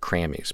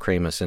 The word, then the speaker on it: Kramies: It looks like Crammies, but it's pronounced Kramus, and it's uh, the EP is called "Of All the Places Been and Everything."